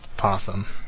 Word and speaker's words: opossum: And I could be wrong, but I believe that opossum is just pronounced possum.